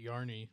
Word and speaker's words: Yarny: And then you call it Yarny so that it rhymes with Arnie.